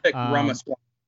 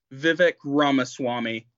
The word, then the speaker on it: Ramaswamy: Ramaswamy. – Hit me with that one more time. – Vivek Ramaswamy.